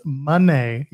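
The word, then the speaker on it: Moné: You have to say it right. Monet not Monet. It's Monet. You got to say it was Mercedes Monet.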